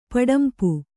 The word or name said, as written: ಪಡಂಪು